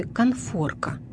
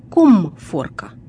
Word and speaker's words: конфорка: Речь идет о слове «конфорка» или «комфорка». Как же правильно? Давайте разбираться. Когда слово «конфорка» только появилось в нашем языке, допускалось и произношение, и написание с буквой «м».